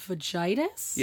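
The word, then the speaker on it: Vagitus: V is for, forgive me if I pronounce this wrong, but vagitis? – Yes, which means, it's a Latin word meaning the cry of a newborn baby.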